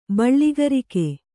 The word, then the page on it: ಬೆಳ್ಳಕ್ಕರಿಗ: beḷḷakfkriga